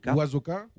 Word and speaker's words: Oizaka: Très rapidement, pour faire, par exemple, si on prend le concert de ce soir, je ne sais pas si je le prononce bien, Oizaka. – Oizaka. – Oizaka. – Parce que ça se trouve, c'est moi qui le dis mal depuis le début. C'est Oizaka, mais effectivement, il y a des gens qui le voient comme Oizaka.